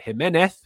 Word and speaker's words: Jimenez: Also, I had someone leave a comment saying you pronounce it Jimenez because he's Mexican. He's not Spanish. You don't say Jimenez. You say Jimenez, which is the same as the Fulham striker, of course. So Jimenez, not Jimenez.